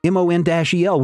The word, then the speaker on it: Mon-El: And the editor responds, we pronounce it Mon-El.